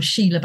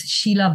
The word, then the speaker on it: Sheela: I'm, you know, my name is pronounced in Bengali. I'm in English, I'm Sheela Banerjee. In Bengali, I was Sheela Banerjee.